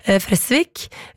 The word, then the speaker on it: Fresvik: Fordi vi var jo innom denne nydelige bygda i stad, hvor festivalen Fres blir arrangert, og jeg sa Fresvik, så kommer Oddvar på ballen her, og påpeker at det skal jo uttales med kort E, dobbelt S, Fresvik.